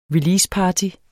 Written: releaseparty

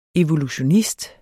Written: evolutionist

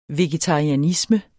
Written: vegetarianisme

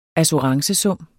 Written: assurancesum

